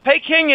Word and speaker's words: Peking: The second not-so-easy one is that when Chiang Kai-shek abandoned and escaped with his life from, back then it was called Peking, Peking is the Cantonese pronunciation, and Beijing is the Mandarin pronunciation.